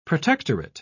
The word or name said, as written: protectorate